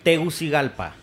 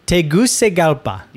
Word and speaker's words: Tegucigalpa: Tegucigalpa. – Tegucigalpa.